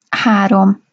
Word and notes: harom: Hungarian

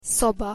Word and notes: szoba: Hungarian

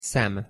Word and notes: szem: Hungarian

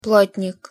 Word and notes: plotnik: Russian

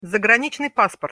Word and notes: zagranicnyj pasport: Russian

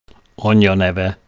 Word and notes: anyja neve: Hungarian

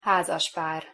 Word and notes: hazaspar: Hungarian

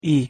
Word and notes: i: Hungarian

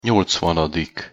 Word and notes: nyolcvanadik: Hungarian

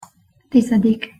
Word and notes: tizedik: Hungarian

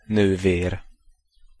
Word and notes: nover: Hungarian

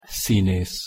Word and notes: szinesz: Hungarian

